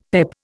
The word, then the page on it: PEP: Indian